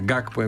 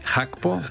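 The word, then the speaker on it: Gakpo: Αυτοί έρχονται χωρίς το Χάκπο, που το λέγαμε χρόνια Γκάκπο εμείς, ενώ προφέρεται Χάκπο, Πεκτούρα Πεκταράς.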